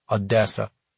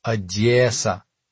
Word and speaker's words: Odessa: In the presence of a native, don't ever pronounce it like the town in Texas. Don't say it as though it rhymes with Vanessa, Odessa. Put a little soulful James Brown spin to the middle syllable. Odessa. Like, yeah, sir.